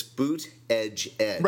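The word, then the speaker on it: Buttigieg: See of that group, based on his performance last night, I think I think, I, and I found out how to say his name. It's Boot Edge Edge.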